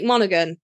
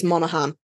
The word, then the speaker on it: Monaghan: So we got Elijah Wood, Dominic Monaghan. That's not how you pronounce that. I think it's Monaghan.